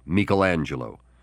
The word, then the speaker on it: Michelangelo: I guess I should have said Michelangelo. That's how they say it in Italy, Michelangelo.